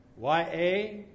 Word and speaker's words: Yahweh: Now, when the consonants are added, the word is very easy to pronounce. YAHWEH is Yahweh.